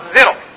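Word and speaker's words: Xerox: إذا أتى بهذا الشكل في بداية الكلمة ينطق زيروكس، ز أو زينون، اسمهم زينون، تبدأ بهذا الحرف.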